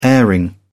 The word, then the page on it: airing: British English